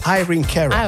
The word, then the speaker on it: Irene Cara: Però la pronuncia corretta è... – Irina. – No, Irene Cara. – Irene. – Irene Cara.